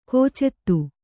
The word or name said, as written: ಕುಚಿತ